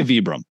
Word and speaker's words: Vibram: I mean, I say Vibram.